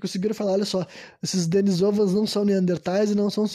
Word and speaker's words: Denisovans: Em inglês, na verdade, é Denisovans. Se escreve D-E-N-I-S-O V-A-N-S, sabe?